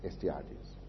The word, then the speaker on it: Astyages: Astyages.